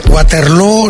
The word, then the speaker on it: Waterloo: Que además te van a dar además la cosita de, oye, y además te tienes que venir aquí a humillar literalmente a Waterloo, pero es que esto no te puede sorprender, pero bueno. – Bueno, lo primero, Waterloo se pronuncia igual que Feijó.